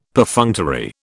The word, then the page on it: Perfunctory: British English